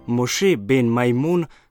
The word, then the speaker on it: Moshe ben Maimon: Moshe ben Maimon, kakor se v hebrejskem jeziku izgovori njegovo ime, je bil človek, ki je mislil grško, pisal arabsko in molil v hebrejščini.